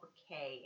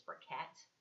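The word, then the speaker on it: Briquet: So some fun history is that somatization disorder was first described by Paul Bricquet in 1859 and was subsequently known as Bricquet syndrome.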